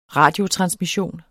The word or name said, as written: radiotransmission